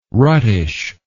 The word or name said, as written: ruttish